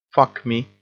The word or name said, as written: fuck me